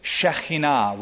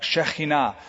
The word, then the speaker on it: Shekinah: It's the Shekinah would be the, I think, correct pronunciation. Avi will correct me if I'm wrong. Shekinah.